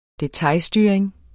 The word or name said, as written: detailstyring